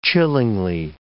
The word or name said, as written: chillingly